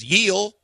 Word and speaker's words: Gil: The pitcher for the Yankees the other night was spelled G-I-L, and they were saying it was Yeel.